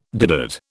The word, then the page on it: doodad: British English